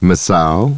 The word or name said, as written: Masao